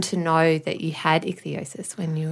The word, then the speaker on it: ichthyosis: So you have a condition, I'm not telling you what you have, but called ichthyosis.